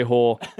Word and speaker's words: Hoare: Usually in college, they would call me Jorge or Jorge, and they just say, I say, mate, it's Hoare, like Lady of the Night. Just pronounce it like that. It's fine. Like, trust me, as an Australian, like, you're butchering it. Just say Hoare.